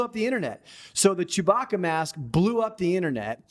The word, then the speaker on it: Chewbacca: Is that how you say it? The Chewbacca mask. Sorry. – Chewbacca.